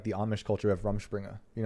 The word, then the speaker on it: Rumspringa: So Miley Cyrus is on her Rumspringa. – Yeah. – That's how you say it.